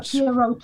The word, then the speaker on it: Pierrot: Which is an image of a if I'm getting this pronunciation right, kind of a Pierrot clown, a Pierrot type clown, yes.